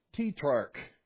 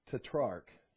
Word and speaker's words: tetrarch: And you ever done that where you go to pronunciation, type pronounce tetrarch. So well, that's not how you say it. It's pronounced tetrarch. Tetrarch.